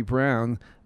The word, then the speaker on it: Braun: He was there, and actually he is on this recording, but I want to play Ari Brown. It's B-R-A-U-N, but they say it's pronounced Ari Brown.